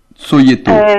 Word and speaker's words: Cojeto: Si vous avez accès à SoundCloud, par exemple, vous pouvez passer le titre Tsoyeto. – Tsoyeto. – Ça s'écrit, comme ça tout le monde le saura, oui. – C-O-J-E-T-O.